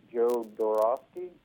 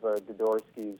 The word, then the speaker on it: Jodorowsky: Jodorowsky. – Yep. – And Mobius, which I think mainstream nerds are most famous of Jodorowsky's work that he wrote that, or he shouldn't, I shouldn't say wrote, he tried to spearhead a Dune movie in the 90s.